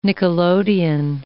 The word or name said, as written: nickelodeon